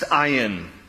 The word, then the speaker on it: ayin: The Hebrew letter of the alphabet is pronounced ayin, ayin.